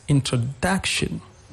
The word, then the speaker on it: introduction: Example, if I take the word introduction in English, the pronunciation of the word introduction, the third syllable has a stress, introduction.